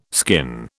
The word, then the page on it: skin: British English